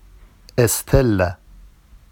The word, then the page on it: estella: Vafsi